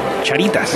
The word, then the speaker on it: Charitas: que allí se conoce como Charitas.